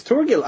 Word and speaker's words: Thorgal: It's Thorgal. I guess. – Type that out for us. – Thorgil. – What? – It's Caligula. It's Caligula. – No, it's Thorgil. I know the comic. – Ah, Thorgal.